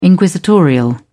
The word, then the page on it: inquisitorial: British English